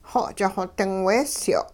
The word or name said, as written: xo'ch xo-ding weesyo'